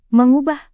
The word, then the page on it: mengubah: Indonesian